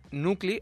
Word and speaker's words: NVCLI: Nucli, en el Nucli, que se escribe N-V-C-L-I, es un espacio bastante chulo que hay por la huerta, de músicas experimentales sobre todo.